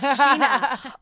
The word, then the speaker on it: Scheana: No one ever knows how to pronounce it. Like even my Alexa says, connecting to Skiana Marie's iPhone. I'm like, it's Sheena.